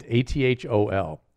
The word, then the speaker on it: Athol: Athol. And they pronounce it like asshole.